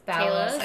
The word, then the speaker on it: Talos: I love R.I.P. Thalos. Like, he was amazing. – Talos. – I can't say his name ever. – Why do you put an H in it? – I don't know.